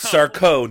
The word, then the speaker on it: Sarko: It's Sarko, no Mister, accent on the co.